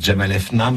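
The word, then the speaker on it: Jemaa el-Fna: La plus belle place d'Afrique, je ne sais jamais comment prononcer, moi je suis nul. Moi je dis la place Jamal Efna, mais c'est exactement ça.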